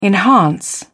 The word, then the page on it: enhance: British English